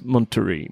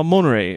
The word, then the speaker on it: Monterey: Jag kommer ihåg att jag Monterey. Gick tillbaka och lyssnade. Ja, Monterey. Eller Monterey. Något sånt. Jag tror inte T-et ska uttalas.